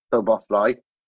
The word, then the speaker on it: Szoboszlai: And great to take an hour out and spend this time with you and talk about So-Boss-Lai. So-Boss, and that's what the name of the pod is, and Midfield, So-Boss-Lai. Easiest way to tell people how to pronounce it.